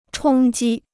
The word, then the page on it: 冲击: chōng jī